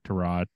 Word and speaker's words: Tyrod: But there's also a chance Tyrod Taylor comes back. Or Tyrod Taylor. Sorry. I know you like your name pronounced the right way.